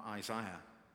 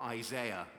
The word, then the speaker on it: Isaiah: Isaiah. Or Isaiah, as my pronunciation was corrected this morning.